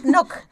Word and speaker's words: Knock: Es que se pronuncia así, Knock.